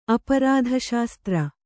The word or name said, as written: ಅಪರಾಧಶಾಸ್ತ್ರ